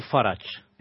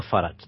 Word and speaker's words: Farage: Partido de Sí, es Nigel Farage. El apellido puede ser de origen francés. El tío se llama Farage. De hecho, le toman el pelo. – Pues lo pronuncian francés.